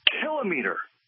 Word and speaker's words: kilometer: Do you see the problem with putting the emphasis on the long syllable? – Yes. – Shouldn't it be pronounced kilometer? – Yes. – We don't say centimeter or millimeter. – We don't say thermometer. – Yeah, kilometer. Kilowatt.